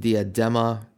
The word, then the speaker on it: diadema: Well, they're called diadems in English. They're pronounced diadema. It's a Greek word.